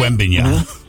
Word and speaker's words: Wembanyama: Wembanyama, am I saying that right? – Wembanyama.